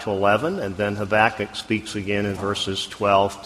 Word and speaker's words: Habakkuk: Some would say Habakkuk. Some would say Habakkuk. If you want to be a little more Hebrewish, you would say Habakkuk. We will put the accent on the second syllable, Habakkuk, primarily.